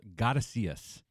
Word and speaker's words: Godisheus: He also fronts this funk group called Gotta See Us. It's spelled G-O-D-I-S-H-E-U-S.